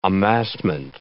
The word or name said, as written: amassment